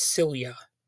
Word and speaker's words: Silje: But the main star who plays Jenny is Silja Salomonsson. And I had to look up how to pronounce that first name because it's spelled S-I-L-J-E. And it turns out that's Silja.